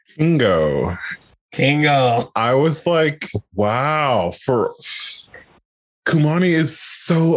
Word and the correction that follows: Kumail: It's Kumail Nanjiani.